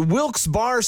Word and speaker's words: Wilkes-Barre: And you're listening to Wilkes Barre Scranton's 97.9X. – Now, I had heard that it was supposed to be pronounced Wilkes-Barre. – Wilkes-Barre Scranton's 97.9X. The only way to pronounce Wilkes-Barre.